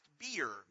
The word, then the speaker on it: bier: It has a different word that's pronounced bier, B-I-E-R. It's pronounced bier.